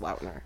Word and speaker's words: Lautner: So I usually say Taylor Lautner, because that's what everyone says. But he did say a long time ago that it is pronounced Lautner.